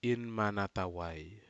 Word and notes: inmana tawai: Aneityum

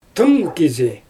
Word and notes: tɯŋ kizi: Xyzyl

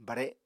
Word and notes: bare: Apatani

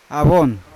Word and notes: avon: Yawa

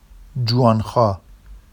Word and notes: ʤuwɑnxɑ: Vafsi

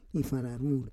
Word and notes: ifararur: Roon

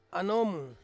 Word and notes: anom: Ansus

